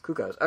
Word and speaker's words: Cuccos: I was calling them Kukos. – Kukos? Kukos? Kukos? C-U-C-C-O-S. – Oh, Kukos. – Kukos.